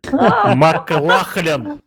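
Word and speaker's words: MacLachlan: Ну вот, например, а, Кайл Макло... – Маклахлен. – Маклахлен. Маклахлен, вот это вот.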